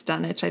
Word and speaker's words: Dunwich: Ruins from this period in Dunwich, I'm thinking it's Dunwich. I don't think the W's are pronounced like Greenwich, right?